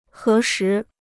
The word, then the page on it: 核实: hé shí